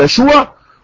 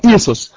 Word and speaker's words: يسوع: في معلومة قلتها ولازم أكد عليها، إن يسوع في العبرية تنطق يشوع وفي اللغة اليونانية إيسوس.